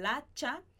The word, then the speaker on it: hacha: Ha enterrado el hacha, enterrado el hacha. Aqui, como a gente não pronuncia o primeiro H da palavra hacha, a gente vai juntar el hacha, el hacha. Então, ha enterrado el hacha de guerra.